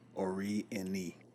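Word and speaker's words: Orieni: But he also is the founder of Ori Enon. Did I pronounce that correctly? – Ori Eni. – Ori Eni. And that's O-R-I-E-N-I.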